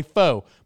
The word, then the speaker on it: pho: It's P H O. Okay, so I would say foe. Sounds like an F, right?